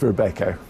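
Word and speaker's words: Robeco: I'm going to go broad here, because it's Rebecca or Rubico. How do you pronounce it? – Depends if you're Dutch. Yeah. It's Rebecca.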